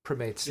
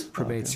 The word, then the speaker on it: primates: Primates. Primates, yes. – Primates? – Primates, okay. Yeah. – I knew it was probably not pronounced primate.